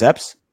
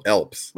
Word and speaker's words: Epps: Did you call him Elps? You butchered at least seven of these names. – Marcus Epps? – Yeah, you call him Elps.